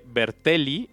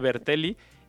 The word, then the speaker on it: Berthely: Bertelli y mi apellido se escribe con B de bueno, después de la T una H e Y al final, P. Bertelli.